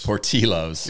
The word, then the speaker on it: Portillo's: Portillo's. – Portillo's. – Yes. – And you were saying Portillo's. – Portillo's.